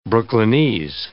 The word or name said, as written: Brooklynese